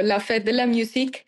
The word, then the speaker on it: La Fête de la Musique: La Fête de la Musique.